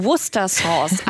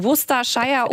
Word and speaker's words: Worcester Sauce: Da sorgt ja die Aussprache immer für Verwirrung. – Ich ahne, was kommt. – Pass auf, die Worcester Sauce. Also nicht Worcestershire oder Worcestershire oder was es da nicht alles gibt. Nein, Worcester Sauce heißt die.